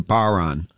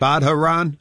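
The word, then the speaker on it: bodhrán: the Irish drum is a baron, and it's pronounced, or it's spelled B-O-D-H-R-A-N. So people are always saying, you playing that badharan?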